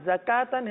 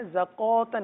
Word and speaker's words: زکاتن: اسی طرح زکاتن ہے زکوتن نہیں ہے, کاف کو باریک پڑھیں گے.